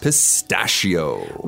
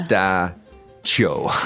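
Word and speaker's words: pistacchio: Well, the CH, right? You guys might think it's got to be like a ch sound. – Yeah. – Pistachio. But this word is much more like pistachio.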